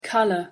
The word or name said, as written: colour